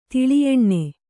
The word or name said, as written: ತುಲಾಯನ